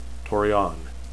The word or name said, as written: torean